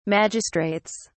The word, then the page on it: magistrates: British English